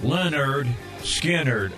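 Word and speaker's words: Lynyrd Skynyrd: The first album said pronounced Leonard Skinner on the front of it.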